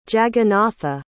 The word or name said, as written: jagannatha